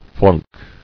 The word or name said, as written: Flunking